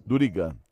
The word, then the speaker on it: Durigan: Durigan, tá ótimo.